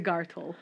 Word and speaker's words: gartel: So in English, you spell it G A R T E L, but it sounds like that should be Gartel. But it's more like it comes from the same word as girdle, the same German word.